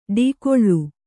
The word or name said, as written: ಟಿಕಳೆ